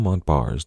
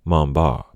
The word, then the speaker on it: Montbars: Now, before we move on, I'd like to point out that Daniel Montbars did not pronounce his name that way. He was French, after all. His last name was pronounced something a little closer to Montbars, but in the interest of not butchering the French language for the entire episode, I'm going to stick with the anglicized version.